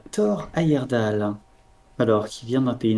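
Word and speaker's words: Heyerdahl: Et euh, notre personne d'aujourd'hui est un certain euh, Thor Ayerdal.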